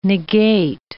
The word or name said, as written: negate